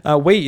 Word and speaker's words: Wii: Wii is pronounced W-Wii, as in Wii.